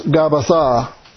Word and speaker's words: Gabbatha: But in the Hebristi, the Hebrew, it's pronounced Gabbatha.